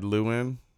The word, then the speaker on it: Llewyn: Inside Llewyn. Am I pronouncing that correctly? – Yeah.